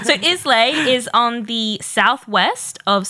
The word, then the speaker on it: Islay: I think it's Islay. – Is it Islay? – Yeah. You said it right the first time.